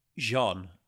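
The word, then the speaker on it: J'onn: Do you prefer a hard J or a soft J on John? – I go Jean. Purely based on the pronunciation in the Justice League cartoon. That's, well, we should probably honour that, shouldn't we? I will try and soften my J's.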